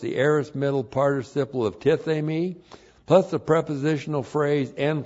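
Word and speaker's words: tithemi (τίθημι): T-I-T-H, long E-M-I, tithemi.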